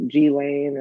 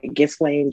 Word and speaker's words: Ghislaine: Like, remember how they were like, there was stuff about G-Lane and like how, I think I'm saying her name right, Ghislaine, G-Lane, but. – Oh, Ghislaine Maxwell.